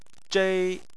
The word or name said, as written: zhei1